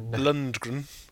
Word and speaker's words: Lundgren: And then at the end, it finishes on that, and we're just in a big Lundgren. Or is that how you say it? – Lundgren. – Lundgren.